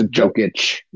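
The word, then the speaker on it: Jokić: But Dokic, or Jokic, not however you pronounce his name, I'm sorry. – Right. – I think Dokic is a tennis player, isn't he? – I think so. – Yeah. – It's Jokic. – Yeah, but Jokic, J-O-K-I-C.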